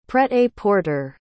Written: prêt-à-porter

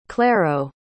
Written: claro

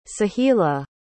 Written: sahila

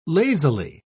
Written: lazily

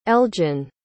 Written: eljin

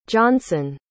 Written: johnson

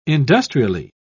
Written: industrially

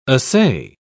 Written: assay

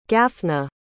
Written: Gafna

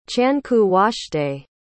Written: chankoowashtay

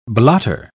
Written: blotter

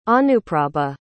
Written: anuprabha

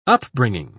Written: upbringing

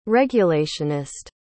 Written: regulationist

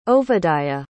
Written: Ovadiah

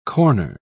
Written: corner

